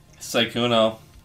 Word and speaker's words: Sykkuno: S-Y-K-K-U-N-O. – Sykuno.